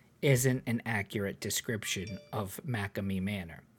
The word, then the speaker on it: McCamey: It's, I can't pronounce it. Macame Manor, MC, big M, little C, capital K, AMEY Manor.